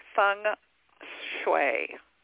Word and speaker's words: feng shui: It's feng shui, like F-U-N-G, feng shui.